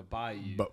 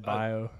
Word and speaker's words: bayou: He said bio. – Bio. – Oh, I might have said bio. I do say bio. – That is not the correct pronunciation, in my opinion.